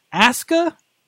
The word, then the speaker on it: Asuka: Japanese pronunciation. WWE's doing one thing right because Japanese pronunciation, you never pronounce the, pronounce the U. – Okay, mm. but it's Asuka.